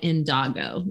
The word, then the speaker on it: Indago: It's called I think it's indigo, but it's called indago. It's spelled indago.